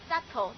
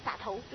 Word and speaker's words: subtle: subtle 哈，看它发音 subtle 对不对？不明显，洒头对不对哈？